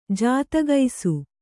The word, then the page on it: ಜಾತಗೈಸು: jātagaisu; kannada